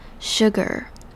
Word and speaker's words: sugar: Sugars 这边首先要注意的是第一个字母虽然是 S, 但是这边是发成 sh 的声音。而这边的 U 是发成 u 的声音 ,sugar。后面的 AR 则是念成 ER 的发音，而不要忘记这边的糖不止一种糖，所以后面有一个 S，大家要清楚的把它念出来，不能忽略它 Sugars。